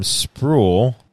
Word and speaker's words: Spruill: and Sam Spruill. I said it wrong. As Swarm. – No, you said it fine.